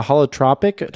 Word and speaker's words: holotropic: Is it the holotropic? – Holotropic.